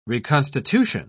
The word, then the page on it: reconstitution: British English